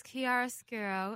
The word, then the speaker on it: Chiaroscuro: Well, the name is Chiaroscuro